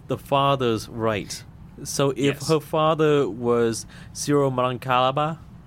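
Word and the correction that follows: Syro-Malankara: I might have said it wrong. I'm sorry. It's Ciro Malankara. – Yeah, that's very good pronunciation.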